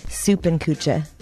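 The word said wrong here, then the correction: Suppenküche: You pronounce it Zuppenküche.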